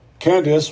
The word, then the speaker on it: Candace: Candace, or pronounced correctly, Candace,